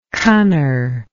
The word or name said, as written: cunner